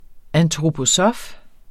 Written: antroposof

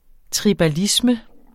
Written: tribalisme

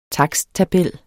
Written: taksttabel

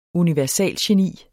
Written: universalgeni

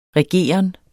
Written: regeren

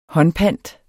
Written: håndpant

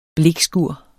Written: blikskur

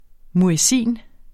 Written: muezzin

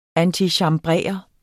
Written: antichambrere

